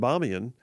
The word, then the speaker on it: Bamiyan: The Bamiyan Buddha, Bamiyan most often is spelled B-A-M-I-Y-A-N, Bamiyan.